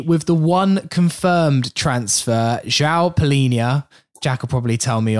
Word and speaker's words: João Palhinha: Well, I'm sure there's going to be plenty of snark for me, but I think it's João Palhinha.